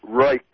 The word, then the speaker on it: Reich: It's not. It's Reich. Reich wing.